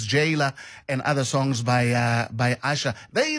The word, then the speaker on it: Asa: Asha, written A-S-A, but pronounced Asha, not Asa, as some people would think. Because as I said, the S in Yoruba is pronounced as sh.